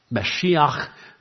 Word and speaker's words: Mashiach: How do you say anointed one in Hebrew? Messiah. Mashiach. You don't say it with a gutter in the back of your, probably don't need to clear your throat, but HaMashiach would be the anointed one.